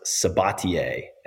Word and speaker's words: Sabatier: My last name is pronounced Sabatier. S-A-B-A-T-I-E-R.